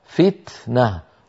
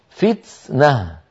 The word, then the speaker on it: فتنة: ثم قال رحمه الله وانظروا ماذا قال: وربما جعلت سينا لا سيما إذا كانت ساكنة نحو فتنة. الصواب فتنة، والخطأ فتنة. تاء يتلوها سين، إذن هذا الخطأ قديم نبه إمامنا الجزري عليه.